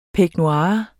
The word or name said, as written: peignoir